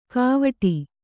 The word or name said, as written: ಗಾವಠಿ